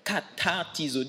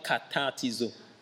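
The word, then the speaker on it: katartizo (καταρτίζω): Perfectionnement, le mot perfectionnement derrière, c'est le mot grec que l'on prononce katartizo. Dis avec moi katartizo.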